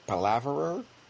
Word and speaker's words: palaver: There's there's there's a word in English that people apply to his style of writing, palaver or palav palaverer. Uh, pa- pal- palaver, I guess, is the way you pronounce it.